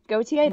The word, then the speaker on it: Gotye: ago, somebody that I used to know by I think Gotye, Gotye, that's yeah, how you pronounce that it.